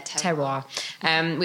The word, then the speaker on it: terroir: I'm probably not pronouncing it right. Is it terroir? – Terroir.